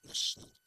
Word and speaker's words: national: National. – National. – No, national. Dos sílabas.